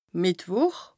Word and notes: Mìttwùch: Bas Rhin; Strasbourg

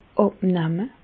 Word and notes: àbnamme: Bas Rhin; Reichshoffen

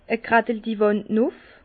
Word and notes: e Graddel-di Wànd nuff: Bas Rhin; Herrlisheim

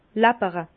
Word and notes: läppere: Bas-Rhin; Herrlisheim

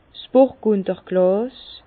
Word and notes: 's Burgunderglàs: Bas-Rhin; Herrlisheim

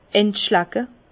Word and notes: entschlacke: Bas-Rhin; Herrlisheim

